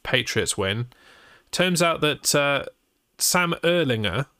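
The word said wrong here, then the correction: Ehlinger: their new QB, it's actually pronounced Ellinger, Sam Ellinger. And I've been saying that wrong since he was in college.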